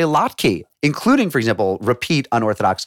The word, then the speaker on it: latke: The only pronunciation I'd ever heard was latke, with a schwa, a little With a little zhuzh there. – A little vowel there.